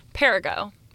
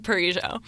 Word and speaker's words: Perigo: Everyone thinks it's like Perigo. – Yeah, I was going to say, I thought it was Perigo. – It rhymes with indigo.